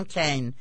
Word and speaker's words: Keane: You do have to hand it to Tom Kane. I know it's spelled Keane, but that isn't how he pronounces it.